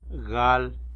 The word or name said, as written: gal